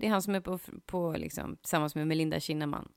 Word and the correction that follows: Kinnaman: Kinnaman, oh my god. – Yes, jag fick rätta dig på ett uttal.